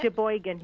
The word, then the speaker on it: Sheboygan: Is that how you say it? – Sheboygan, yes.